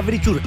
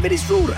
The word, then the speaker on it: Verisure: Claro, muchachos, no diga Verisure, es Verisure, así como se escribe.